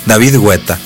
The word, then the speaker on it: David Guetta: Dije, sí, no alcancé a ver Pitbull, no voy a intentarlo con David Guetta, que le digo que es la manera correcta de pronunciarlo. – En Francia, sí, en su país natal, se dice David Guetta, es correcto.